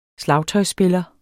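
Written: SLAGTØJSSPILLER

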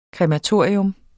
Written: krematorium

